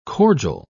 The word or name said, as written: cordial